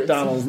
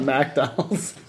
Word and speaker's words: McDonald's: It's like McDonald's, not MacDonald's.